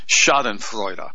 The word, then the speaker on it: Schadenfreude: Schadenfreude. And that's S-C-H-A-D-E-N-F-R-E-U-D-E, if you want me to spell that for you.